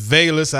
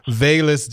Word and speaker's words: Velus: It's Velus. I've been mispronouncing your name and my bad on that one. So going forward, I'll make sure I get that together. Velus Jones Jr., pardon me.